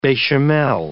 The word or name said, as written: bechamel